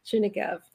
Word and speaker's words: Chernigov: Is Shlucha to, and I'm going to mispronounce it, Chernigov, Ukraine? – Chernigov, Ukraine.